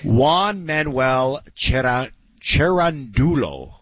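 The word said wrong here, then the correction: Cerúndolo: The way they say it on the broadcast, it's Cerundolo. – Cerundolo.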